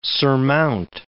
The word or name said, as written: surmount